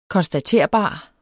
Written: konstaterbar

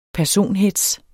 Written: personhetz

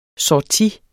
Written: sortie

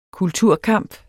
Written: kulturkamp

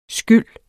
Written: skyld

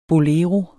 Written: bolero

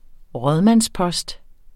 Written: rådmandspost

